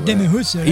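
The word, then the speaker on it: Demi Roussos: Demi Rousseau. Como é que fala mesmo? – Demi Rousseau. – Isso aí.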